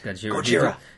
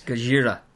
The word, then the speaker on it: Gojira: No, it's pronounced Gojira. – Gojira. – Did you not see Ken Watanabe? It's Gojira.